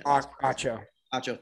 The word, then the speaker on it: Acho: Acho, thank you.